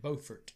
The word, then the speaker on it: Beaufort: Spelled just like Beaufort here in South Carolina, but they pronounce it Beaufort.